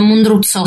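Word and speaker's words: Mundruczó: E Pieces of a Woman è il primo film in inglese, credo, di un regista ungherese che si chiama Cornel Mundruzzo. – Si dice Mundruzzo, si Mundruzzo. Deve pronunciare con mio disappunto quando l'ho intervistato.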